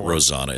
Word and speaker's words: Rosanna: Well, he probably has she pronounces it Rosanna. It's her name.